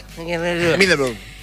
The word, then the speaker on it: Middlesbrough: Middlesbrough.